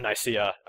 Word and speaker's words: Nicaea: It's always been Nicaea. Everyone who thought it was Nicaea, you've been wrong all these years. Deal with it. – you're pronouncing it wrong.